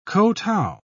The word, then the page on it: kotow: British English